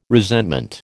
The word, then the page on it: resentment: American English